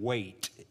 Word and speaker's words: Waites: But if you watched it this week, and it has made national news, especially within Christian traditions, Ben, and I think he actually pronounced his name, last name, Waite. It's spelled W-A-I-T-E-S.